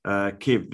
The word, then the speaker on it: Kyiv: Kyiv, which is the Ukrainian capital, I assume, and I had to be taught by the ABC how to pronounce it. Kyiv, the US have pulled out of their embassy and destroyed all their IT phone networks.